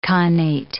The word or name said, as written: khanate